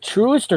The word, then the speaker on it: Truist: Is it Truist or Troist? – Truist. – Pronounced phonetically, apparently.